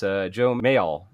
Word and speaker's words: Joe Mayall: It's Joe Mayall.